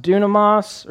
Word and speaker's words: dunamis: And what's cool about this word power is it's actually, it's pronounced dunamis or dunamis, or sometimes the English renders it dynamis.